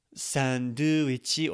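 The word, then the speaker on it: Sandoichi (サンドイッチ): Sandoichi or sandoichi is the way this is pronounced.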